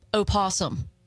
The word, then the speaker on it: opossum: I don't like that you say opossum. – I'm sorry. Hold on. Hey, they possums. – They are. The O is silent.